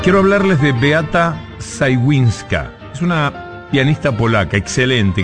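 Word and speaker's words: Szałwińska: se escribe S-Z-A-L-W y después, como suena, Zajwińska.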